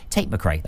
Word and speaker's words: Tate McRae: Tate McRae. That's right. Yeah, that's how you say her name.